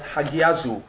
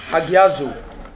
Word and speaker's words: hagiazo: It's called hagizu. Hallelujah. Hallelujah. Hallelujah. Or pronounced hagiazu. Hallelujah. Either hagizo or pronounce agiazo. Hallelujah. Hallelujah. Glory to God. Not Pastor Agizo. Hallelujah. Hagiazo. That's better pronounced.